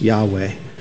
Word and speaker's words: Yahweh: At least we think that's how it's pronounced, Yahweh.